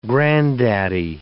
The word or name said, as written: granddaddy